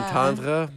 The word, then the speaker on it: entendre: It's got that weird French R at the end, yeah. Like entendre.